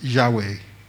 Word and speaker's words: Yahweh: Today, we pronounce it as Yahweh or Jehovah.